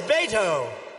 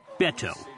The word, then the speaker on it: Beto: Ted's opponent in this race is a stone-cold phony named Robert Francis O'Rourke, sometimes referred to as Beto. – Actually, it's pronounced Beto, a nickname for Robert that he picked up in his hometown of El Paso.